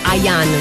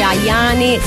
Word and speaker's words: Ayane: suona il 2012 su Radio Latte Miele, tre cose di Malika Ayane. Non si dice Ayane, si dice Ayane, si scrive con la Y tra le due A del cognome.